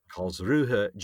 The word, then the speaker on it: Karlsruhe: She was born on this day in Karlsruhe. I don't know if that's how you say it. – Karlsruhe, Germany, in 1976.